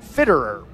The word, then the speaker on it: Fitterer: Kind of sounds like you're stuttering. That's how you pronounce his name, Fitterer.